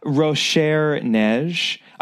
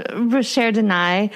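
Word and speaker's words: Rochers de Naye: So, reluctantly, Poirot stays on this rather claustrophobic funicular, headed down to the top of the mountain in a place called Rochère Neige, I believe.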